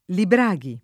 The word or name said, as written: Libraghi